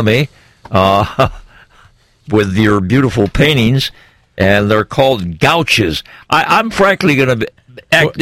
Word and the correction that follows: gouache: Well, the way you pronounce it, I'm not familiar with it either. But it's called gouache.